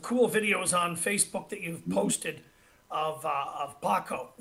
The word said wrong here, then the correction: Pacho: Is that how you say it, Paco? – Pacho. – Pacho. Pacho. Yeah.